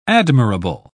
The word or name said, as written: admirable